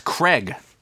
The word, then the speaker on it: Craig: It's Craig. – Craig Bird. Jeez. Sorry, Craig. I don't know why I said Greg. – Or Craig if Bird. If you pronounce it the British way, because it's an I, Craig.